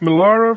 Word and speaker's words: Millar: Geen Mark Millar of zo? Nee, dat spreek je trouwens ook Miller uit, Mark Miller. – Millar. – Ja, Millar.